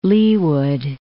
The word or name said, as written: Leawood